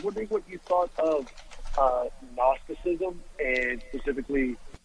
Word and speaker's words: Gnosticism: Gnosticism is a philosophical view that deals with knowledge, from the Greek gnosis, which Gnosticism, in English, the G is silent.